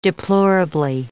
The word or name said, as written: deplorably